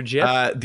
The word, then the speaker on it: GIF: Not a GIF, your GIF? – The actual guy that created him came out and said it's pronounced GIF.